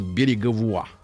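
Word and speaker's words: Bérégovoy: В 25 году Пьер Береговуа, ну то есть Береговой, наш человек, а по-французски Береговой читается как Береговуа.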